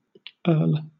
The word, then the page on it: earl: British English